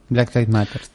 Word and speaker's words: Black Lives Matter: Black Lives Matter, ¿se dice así? Sí, Black Lives Matter.